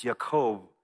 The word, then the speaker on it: Yaakov: His name is Jacob, which technically is pronounced Yaakov. It sounds like the word for heel, which is why they gave him that name.